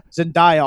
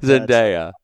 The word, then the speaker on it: Zendaya: You can call Zendaya all you want. But Zendaya. – That's not how you say it.